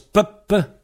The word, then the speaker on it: pep: Voilà, alors chaque c'est pep. Ça se prononce pep, P-E-P, pep. Mais quand il se trouve devant un mot qui commence par une voyelle, si ça commence par une voyelle, le mot qui suit, ça devient pub, P-E-B.